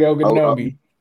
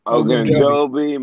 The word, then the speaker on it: Ogunjobi: Larry Ogunjobi. – Larry Ogunjobi. – Ogunjobi, Mike Daniels, yeah.